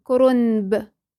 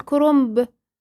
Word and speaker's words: كرنب: كرنب. طيب فين ليه ما بنيجي نقولها بنقول كرنب؟ يبقى احنا عملنا ايه؟ قلبنا النون، رغم انها بتتكتب بالنون، هي تتكتب بالنون ولكن تتنطق بالميم.